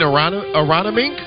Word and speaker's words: Aronimink: Aronimink in Philadelphia at the PGA Championship. – You mean Aronimink? Aronimink. I believe I learned how to say it correctly.